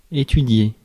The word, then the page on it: research: /ɹəˈsɜːtʃ/; /ˈɹiː.sɜːtʃ/; American English; /ˈɹi.sɚtʃ/; /ɹiˈsɝtʃ/